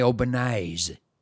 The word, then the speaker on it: Albanese: Are you aware that Anthony Albanese is not how you say Anthony Albanese? – No, I do not. How do you say it? – You say Anthony Albanese. – Is that true? – Yeah, that's well. That's how you pronounce it in Italian.